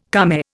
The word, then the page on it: kame: Indian